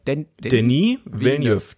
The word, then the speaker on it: Denis Villeneuve: Heißt er Danny oder Dennis? – Denis Villeneuve. Das habe ich sogar noch nachgeguckt. Also das ist endlich mal die korrekte Aussprache. Wir haben lange spekulieren, aber Denis und dann Villeneuve. Okay. Wir haben es sogar fast richtig gehabt.